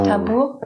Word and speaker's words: Tambur: Tambur, ja, genau. genau. Ähm, auch, gut auch gut ausgesprochen.